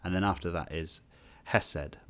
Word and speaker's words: hesed: hesed.